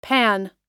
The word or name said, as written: panne